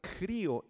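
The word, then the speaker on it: crío (χρίω): Es la palabra griega crío X-R-I-O, con acento en la I, crío que significa un derramamiento de aceite sobre la cabeza de una persona para consagrarla a Dios.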